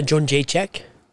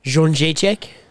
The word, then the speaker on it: Jędrzejczyk: Joanna Jędrzejczyk. – God. – Jędrzejczyk.